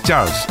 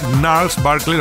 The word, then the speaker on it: Gnarls Barkley: Pues oye, em, no es Charles, es Niles Barkley, lo que sí que es cierto que es más fácil leer Charles, si no lo conocen mucho ese nombre, que Niles. Porque realmente Gnarls em, se escribe con G.